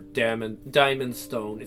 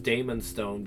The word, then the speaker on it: Daemonstone: I don't want to do that with Dead Man's Lore, Shadowed Sun, or Diamond Stone. It's actually pronounced Damon Stone because it was originally D-A-E-M-O-N-S-T-O-N-E.